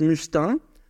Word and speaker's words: Mustin: That's why in French it's like my last name is Mustin, so I pronounce musty. Yeah, right. That's, yeah, because must, I don't want to be musty or rotten.